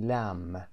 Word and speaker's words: lamb: البته به بره lamb میگیم، بی تلفظ نمیشه در pronunciation ملاحظه میفرمایید بی تلفظ نمیشه و ما میگیم لم، اما به گوشت گوسفند هم میگیم لم که نمبر 6 هست.